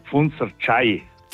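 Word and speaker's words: Pfundser Tschey: Wie heißt das wunderschöne Gebiet oberhalb von Pfunz, was geschrieben wird Pfunzer, T-S-C-H-E. Ich habe gesagt Pfunzer Tsche. Und jetzt in der Leitung. Wie heißt es denn richtig? – Ja, grüß Gott. Das heißt Pfunzer Tschei. – Pfunzer Tschei. Okay, das heißt, die Daniela hat recht gehabt. Jetzt muss ich fragen, warum schreibt man das dann mit E und J? – Weil es uralter Ausdruck ist, Tschei.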